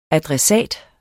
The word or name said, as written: ADRESSATEN